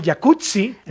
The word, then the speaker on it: jacuzzi: Oye, así que el jacuzzi. – Jacuzzi. – Entonces deberíamos pronunciar el jacuzzi. – Exacto. – Como pizza. De... como Rampazzo, sí. Como Claudia Rampazzo.